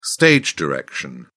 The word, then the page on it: stage direction: British English